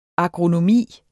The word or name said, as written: agronomi